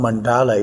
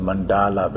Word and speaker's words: mandala: So the term mandala itself is originated from the Sanskrit language, and actually is not the mandala, but it's mandal. – Mandal is in the proper pronunciation of the mandalas.